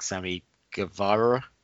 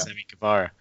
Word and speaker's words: Sammy Guevara: I was like, man, this guy is I really like Sammy Guevara. That's how you say his name, yeah. Sammy Guevara.